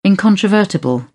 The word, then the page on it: incontrovertible: British English